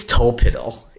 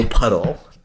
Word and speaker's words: Tolpuddle: I think it's Tolpiddle, even though it's spelled okay. Tolpuddle.